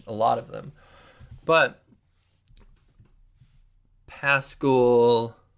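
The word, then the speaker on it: Paschall: Another guy is Eric Paschal. That's how I'm gonna pronounce it. I think that's what I've. Yeah, I'm pretty certain that's how you pronounce his name. I think I've said it incorrectly before.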